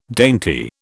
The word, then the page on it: dainty: British English